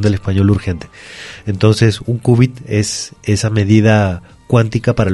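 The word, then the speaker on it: cúbit: Entonces, perfecto, es un qubit, se prefiere esa escritura, qubit con acento en la U y con C, uh-huh, y no las formas en inglés que tienen que ver con la letra Q.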